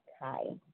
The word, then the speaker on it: Kai: For those of you who don't know me, my name is Kai. That rhymes with sky, pie, and try.